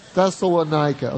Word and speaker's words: Thessalonica: Thessalonica. Okay. So Paul goes to a couple other places, and then he goes to Thessalonica, where there was a Jewish synagogue.